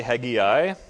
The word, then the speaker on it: Haggai: Haggai, or as the British say, Haggai, lived in, or is ministering here in the year 520.